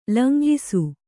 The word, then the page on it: ಲಂಗಿಸು: langisu